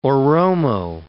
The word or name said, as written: Oromo